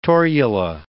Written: torula

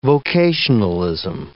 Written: vocationalism

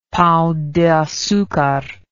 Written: pao de acucar

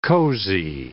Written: cozy

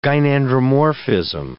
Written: gynandromorphism

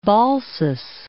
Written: Balsas

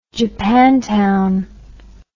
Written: japantown

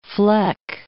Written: fleck

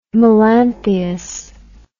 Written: melanthius

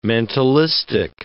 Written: mentalistic

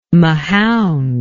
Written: Mahound

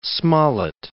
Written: Smollett

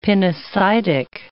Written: pinocytic

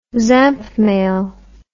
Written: zapmail

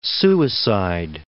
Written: suicide

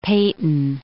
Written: Paton

